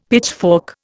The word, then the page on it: pitchfork: Indian